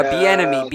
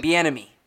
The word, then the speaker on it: Bieniemy: Uh, how do you say his last name? Bettelme. – Uh, uh Bieniemy. – Bieniemy. Yeah, Bieniemy.